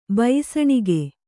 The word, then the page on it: ಬಯಿಸಣಿಗೆ: bayisaṇige